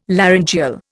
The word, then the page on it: laryngeal: Indian